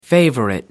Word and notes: favorite: American English

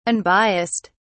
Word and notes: unbias(s)ed: British English

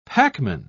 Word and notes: packman: British English

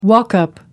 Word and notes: walk-up: American English

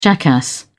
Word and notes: jackass: British English

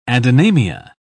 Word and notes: adynamia: British English

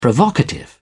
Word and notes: provocative: British English

